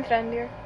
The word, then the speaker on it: Mithrandir: Oh, how do you say it? Mithrandir? – Mithrandir. – Mithrandir? Mm-hmm.